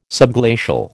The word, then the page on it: subglacial: American English